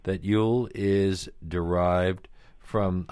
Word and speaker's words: jul: One says Yule, spelled J-U-L, but pronounced the same, is the word for Christmas in Norwegian, Swedish, and Danish, even today.